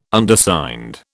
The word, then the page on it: Undersigned: British English